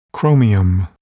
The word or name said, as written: chromium